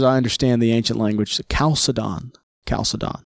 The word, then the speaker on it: Chalcedon: Well, let's get started off with the Council of Chalcedon. – Yes, Chalcedon, the Council of Chalcedon. There's no ch. You'll hear that Chalcedon.